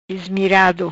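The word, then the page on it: Ismirado: Patuá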